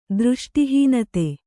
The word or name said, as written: ದೃಷ್ಟಿಹೀನತೆ